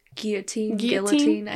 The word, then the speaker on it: guillotine: I have the manual guillotine. Am I saying that right? – Guillotine. I hear people say it both ways.